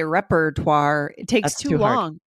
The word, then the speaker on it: repertoire: So you might have trouble spelling this word if you're hearing native speakers say repertoire and you don't hear that R in the middle.